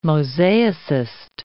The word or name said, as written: mosaicist